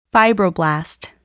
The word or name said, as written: fibroblast